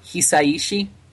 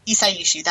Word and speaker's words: Hisaishi: You mean Joe Hisaishi? – Hisaishi, that's his name.